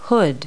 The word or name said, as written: hood